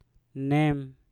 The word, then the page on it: nem: Mpur